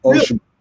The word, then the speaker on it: Oshima: But Oshima. – Really? It's not she. – Hmm? – Phonetically in Japanese, that's not Oshima. – Oshima. It's very monotone, the Japanese. It doesn't have the intonations, you know what I mean? – Okay.